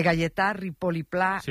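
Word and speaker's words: Gaietà Ripoll i Pla: a ver si lo digo bien, de Galletà Ripoll i Pla, un mestre y pensador que tenía la seua escola a l'Horta de Rusafa. – Perfecto. – ¿Lo he dicho bien? – Sí, vale. Y se ha entendido perfectamente.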